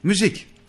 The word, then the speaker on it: Müz: Musa diye yazılsa da Müz diye okunuyor.